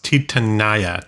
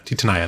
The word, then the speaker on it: Titania: I'm gonna take a hard stance here and say it's pronounced Titania. Titania. I think that's how we should do it.